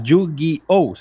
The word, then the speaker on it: Yu-Gi-Oh!: Y por último, Máximo Rollito, desde Twitter también nos decía que está viciado al Yu-Gi-Oh! ¿Se pronuncia así? – ¿Yu-Gi-Oh? – Sí.